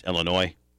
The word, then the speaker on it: Illinois: It's pronounced Illinois. It's not plural. It's singular. It's a French derivative.